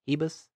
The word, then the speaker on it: Ribas: We got a possibly buzzing star in the women's strawweight division in Amanda Ribas. That's how you say it. She's Brazilian, so I think it's Ribas.